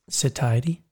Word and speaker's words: satiety: Satiety. – How come I can't say that? – Like satiety. You can't say the word war road either.